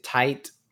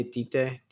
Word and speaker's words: Tite: So, I mentioned it for the last two episodes, but uh, Bleach creator, uh, again, you might pronounce it tight, but I say Tite Tite Kubo.